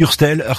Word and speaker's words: Hurstel: Elena Hurstel, je le dis bien, ton nom, ça va? – Hurstel, mais on peut dire comme... Hurstel. – Comme tu veux.